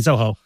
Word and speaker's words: Zoho: So more like Zolo, maybe. – Zolo? Do you mean Zoho? – Pardon? – Yeah. Zolo. Zoho. Zoho. – Yeah, Zoho. Sorry, it's my pronunciation.